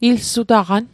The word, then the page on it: ilsutaqan: Mi'gmaq